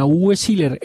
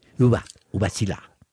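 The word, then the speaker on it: Uwe Seeler: Sí, a Uwe Siller, el gol que le hace... – No, es que lo pronunciaste mal. Uwe Siller.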